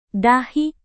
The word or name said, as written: dahi